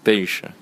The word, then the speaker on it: Penche: Alors, Kériège, je m'appelle Marc Penche. Ça s'écrit à l'anglaise. P-E-N-C-H-E.